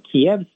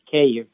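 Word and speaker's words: Kyiv: The city council and the mayor embraced this name change from Kiev Street to Kiev Street. So now we have the street name in the official Ukrainian style of spelling and pronunciation.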